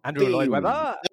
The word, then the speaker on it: Andrew Lloyd Webber: Android Lloyd Webber. Webber. Ah! Can't even say your name. Andrew Lloyd Webber. – Oh, fuck's sake. – Andrew Lloyd Webber.